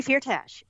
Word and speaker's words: Firtash: Firtash? – Dmitry Firtash, yes.